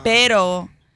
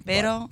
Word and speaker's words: pero: Perro with two R's is a dog. – Pero is how you say it, yeah. Pero or perro. – Pero. – Double R. – Yeah, if they really roll the R's, you got a dog.